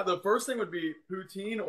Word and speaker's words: Poutine: But okay, so am I saying it right again? Poutine? – Poutine, that's okay.